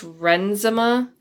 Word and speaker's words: Renzema: It reminds me of a song by Chris Renzema, is how you pronounce his last name.